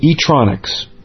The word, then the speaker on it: Etronics: The Sony ECMMS-907 single point stereo microphone is available at its cheapest price from Etronics, which is spelled E-T-R-O-N-I-C-S.